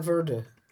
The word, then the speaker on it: Verde: I don't hear Verde for V-E-R-D-E. – Ever. – But I think probably because of its connection to Portugal. So we probably pronounce it the Portuguese way, which we all agreed apparently drops the E, most of the E sound. – Well, it'd be like more like Verde.